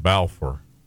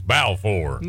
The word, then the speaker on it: Balfour: I wanted to call it Balfour. And I had an old country gentleman to correct me real quick. It's Balfour. – That's right. – It's Balfour.